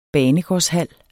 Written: banegårdshal

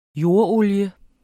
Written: jordolie

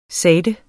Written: sagte

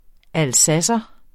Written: alsacer

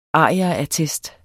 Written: arierattest

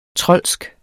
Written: troldsk

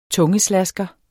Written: tungeslasker

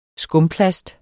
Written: skumplast

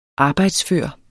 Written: arbejdsfør

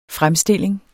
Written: fremstilling

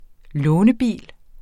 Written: lånebil

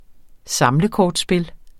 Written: samlekortspil

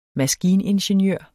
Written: maskiningeniør

